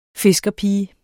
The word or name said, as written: fiskerpige